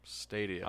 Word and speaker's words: Stadia: It's called Stadia. – The stream, it's plural for stadium. Like that's the actual proper way to say it, apparently. – Stadia.